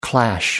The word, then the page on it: clash: American English